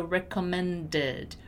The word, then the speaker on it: recommended: And my pronunciation is actually really going to emphasize the ED. So recommended, recommended, recommended, recommended. And when I have it right before the word you, I can actually link them together and pronounce the last D as J, right?